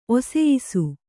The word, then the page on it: ಒಸೆಯಿಸು: kannada